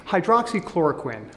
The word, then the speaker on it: hydroxychloroquine: Hydroxychloroquine, I'm glad I pronounced it correctly.